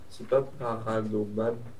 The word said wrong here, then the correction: paronomase: Presque ça, tu l'as. Paronomase. – Paronomase.